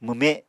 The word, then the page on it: mwme: Apatani